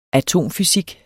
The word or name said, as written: atomfysik